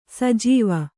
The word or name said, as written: ಸಜ್ಜೀವ